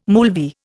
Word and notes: moolvi: Indian

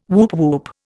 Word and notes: woop woop: Indian

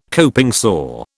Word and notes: coping saw: British English

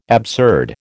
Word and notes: absurd: American English

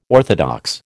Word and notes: orthodox: American English

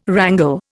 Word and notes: wrangle: Indian

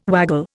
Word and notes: waggle: Indian

